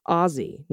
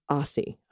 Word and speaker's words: Aussie: It's pronounced Aussie, not Aussie.